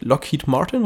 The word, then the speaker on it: Lockheed Martin: Man muss dazu nochmal sagen, dass Boeing, das ist, äh, die machen das zusammen mit Lockheed Martin, richtig? – So mhm.